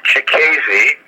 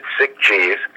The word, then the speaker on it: Cicchese: My last name is spelled C-I-C-C-H-E-S-E. – Thank you very much. – It is pronounced Chicasy, tell you that my computer pronounces it sick cheese.